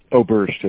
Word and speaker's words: Oberst: Oberst, O-B-E-R-S-T, Jim.